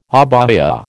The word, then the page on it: abaya: American English